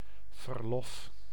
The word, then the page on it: verlof: /vərˈlɔf/